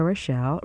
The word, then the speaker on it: Rochelle: First, my name, and I'll spell it for you, Rita Rochelle. Rita is R-I-T-A. Rochelle is R-O-C-H-E-L-L-E.